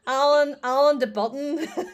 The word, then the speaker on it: Alain de Botton: Alain de Botton.